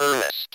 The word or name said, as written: earnest